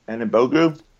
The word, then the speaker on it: Anigbogu: and the the freshman whose name and and Anigbogu. – Anigbogu, you got that one right.